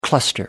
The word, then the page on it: cluster: American English